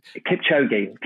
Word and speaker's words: Kipchoge: Kipchoge, The Last Milestone.